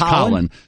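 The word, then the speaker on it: Khalen: I think Colin Saunders, it's K-H-A-L-E-N, pronounced Colin Saunders, defensive tackle, Western Illinois.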